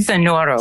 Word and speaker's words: Sonoro: Today, she is composing original work for Sonoro. Did I pronounce that correctly? – Sonoro. – Sonoro. Correct.